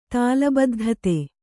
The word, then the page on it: ತಾಲಬದ್ಧತೆ: tāla baddhate